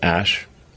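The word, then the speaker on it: Æ: I mean, it's just X, the letter X, and then the AE is like pronounced Ash.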